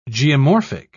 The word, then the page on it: geomorphic: British English